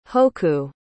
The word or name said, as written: hoku